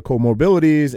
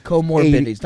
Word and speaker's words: comorbidities: Did they really die of corona or was it corona that put them over the edge because they had 2.6 other comorbidities and were comorbidities, not comorbidities.